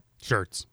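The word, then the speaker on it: Schertz: And immediately afterward, it appears Josh Schurz, did I say that right? – Schurz.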